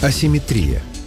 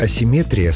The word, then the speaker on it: асимметрия: А асимметрия вошла позже, уже как термин научного языка, ближе к греческому оригиналу, где ударение было иным. Вариант асимметрия словарями не поддерживается и считается ошибочным. Так что в русском языке симметрия есть, а вот асимметрии нет. Запомнить легко. Понятие «симметрия» — это когда все ровно и по центру, даже ударение стоит по центру слова. А вот асимметрия — уже с нарушением баланса, поэтому и ударение съезжает к концу.